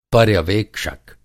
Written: पर्यवेक्षक

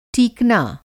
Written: टीकना

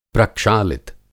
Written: प्रक्षालित

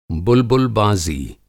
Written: बुलबुलबाज़ी

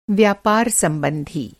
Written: संबंधी

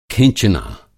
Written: खिँचना

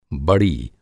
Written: बड़ी

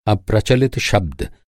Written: अप्रचलित

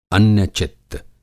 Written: अन्य-चित्त

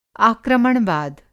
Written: आक्रमणवाद

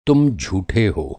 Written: झूठा

